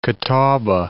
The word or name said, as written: Catawba